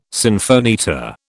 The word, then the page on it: sinfonietta: British English